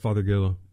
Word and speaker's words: Fothergilla: Yeah, I always call it Fothergilla. – Oh, yeah, there's, there is an L in there.